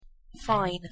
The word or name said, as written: fine